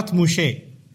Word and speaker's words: Moshe: That's why the Jews call it Torah Moshe. That's how they pronounce it.